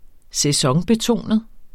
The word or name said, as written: sæsonbetonet